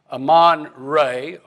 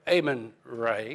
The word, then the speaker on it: Amon-Re: But the supreme deity in their national pantheon of gods was Amon-Re, or Amon-Re. You can pronounce it either way.